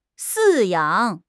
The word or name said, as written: 饲养